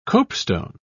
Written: copestone

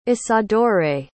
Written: isadore

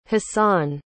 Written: hasan

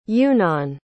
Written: eunan

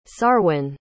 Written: sarwin

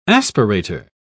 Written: aspirator

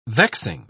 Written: vexing

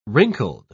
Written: wrinkled

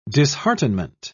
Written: disheartenment

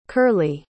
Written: curley

